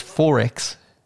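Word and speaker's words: XXXX: So he got the quadruple X dry lager. – You're gonna have to say it correctly. That's not how we say it in Australia. It's 4X.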